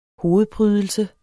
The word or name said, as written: hovedprydelse